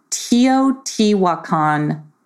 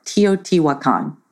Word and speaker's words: Teotihuacan: And so Monia goes to Teotihuacan in Mexico. And at least that's what YouTube told me how to say it, Teotihuacan.